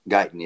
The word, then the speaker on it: Guyton: But this, how do you say, Guyton? – Guyton, yeah.